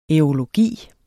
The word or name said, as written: aerologi